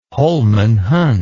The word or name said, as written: holman hunt